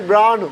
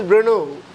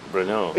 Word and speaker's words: Brno: I first is called as Brno. – Uh-huh. – Now I know it's calling as Brno. – Brno.